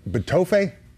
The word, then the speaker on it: Betofe: Is it Betofe? – Yes, perfect.